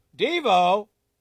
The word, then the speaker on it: Divo: What's the name for the French brand of luxury sports car that includes, I'm going to take a shot at these, Veyron, V-E-Y-R-O-N. I would have never gotten this, by the way. Chiron, C-H-I-R-O-N, and Devo. Oh, Devo. Devo. but it's spelled D-I-V-O.